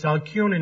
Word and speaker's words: Alcyone: It's Alcyone, not with a K, but with a C. It's a hard C.